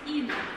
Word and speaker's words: Inno: Inno, вот Михаил Эдемилин уже называл эту доску, на самом деле правильно произносить Inno, Polyvision Inno. Inno – это обратное прочтение слова one, то есть один или одна.